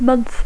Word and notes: month: English

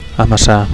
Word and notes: amassar: Catalan

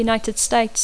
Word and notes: United States of America: English

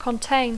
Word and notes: contain: English

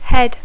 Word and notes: head: English

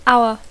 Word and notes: hour: English

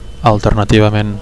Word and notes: alternativament: Catalan

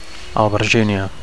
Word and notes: alberginia: Catalan

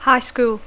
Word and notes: high school: English

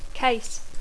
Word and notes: case: English

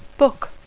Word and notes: book: English

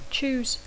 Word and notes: choose: English

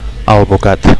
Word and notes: alvocat: Catalan

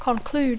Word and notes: conclude: English

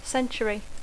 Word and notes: century: English

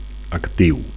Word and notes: actiu: Catalan

